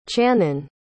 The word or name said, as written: channon